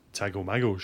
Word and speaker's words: Tago Mago: And I don't know how you pronounce this. Tago Mago? – Tago Mago, surely.